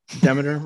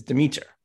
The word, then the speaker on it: Demeter: Of course, I've been saying Demeter my whole life, which maybe is not going to score her points in the iconography round, but the correct pronunciation, as it turns out, is Demeter.